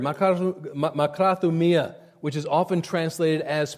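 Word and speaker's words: makrathumia (μακροθυμία): Looking up the word in the Greek dictionary, makrathomia. I'm not a Greek scholar, but that's how they say to pronounce it, makrathumia.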